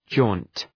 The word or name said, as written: jaunt